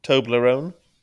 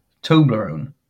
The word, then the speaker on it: Toblerone: Emma says I mispronounce Toblerone. She says I put the emphasis in the wrong place. How would you say it again? – Toblerone. – Yeah, you are. You're putting the emphasis on the wrong syllable, mate.